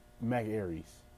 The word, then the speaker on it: Ayres: That's Mac, and then Ayers is spelled A-Y-R-E-S. Is that Aries? I think it's Mac Aries. Yeah, Mac Aries. The name of that track is called Easy by Mac Aries. A-Y-R-E-S.